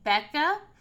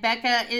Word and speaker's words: Beckah: If you are looking for me on Twitter, I'm at Mary Becca. And Becca is spelled B-E-C-K-A-H.